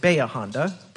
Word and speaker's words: Bahia Honda: Also, coming up this Saturday at Baya Honda, we locals pronounce it Baya Honda, Brandon. But it does look like Bahia Honda.